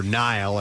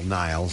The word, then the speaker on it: Nihil: Available for pre-order are figures of the elderly Papa Neil character, or Nile, I guess is how you say his name. N-I-H-I-L, Nile, so annihilation, nihilistic.